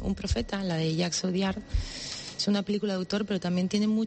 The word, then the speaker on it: Audiard: Y concretamente, las películas que has elegido tú son Un profeta y de Jacques Audiard, no sé si lo he dicho bien. – Audiard.